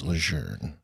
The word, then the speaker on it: Lejeune: That was so precisely pronounced, Lejeune.